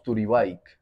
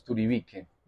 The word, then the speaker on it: Turibike: Eh, si lo pronunciáramos en español, pues como lo dicen algunas veces, algunas veces las personas, es turibique.